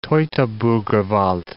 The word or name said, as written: Teutoburger Wald